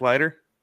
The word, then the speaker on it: Leiter: Jack Lytle. Is that how you say it? The Vanderbilt pitcher. – Oh, Jack Leiter? – Leiter.